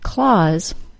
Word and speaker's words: Claus: The vowel sound in Santa's last name, Claus, is pronounced with the A-W sound, aw. C-L-A-U-S-E and C-L-A-W-S, like a cat has, are homophones and are pronounced exactly the same.